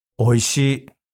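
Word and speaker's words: Oishi (おいしい): Oishi. – Oishi. That's it? – Yeah, well, I said it with emotion, but oishi. – Oishi. Easy. Oishi. Yes. – Yeah, you can always say oishi desu.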